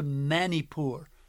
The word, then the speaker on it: Manipur: And I was sharing with him that we had plans to go to Manipur, as it's pronounced, where we were helping a church there to regain their evangelistic focus.